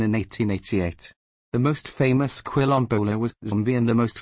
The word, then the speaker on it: Quilombola: A Quilombola, Portuguese pronunciation, Quilombola, is an Afro-Brazilian resident of Quilombo settlements first established by escaped slaves in Brazil.